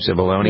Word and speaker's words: Cipollone: I think it's how you pronounce the name, Cipollone. – Yes.